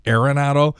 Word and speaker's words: Arenado: A lot of people are saying Arenado, and he sort of made it a point that the correct pronunciation is Arenado, which I find hilarious, given the fact that for 20 years he's been mispronouncing the name Rick Ankiel.